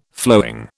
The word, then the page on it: flowing: British English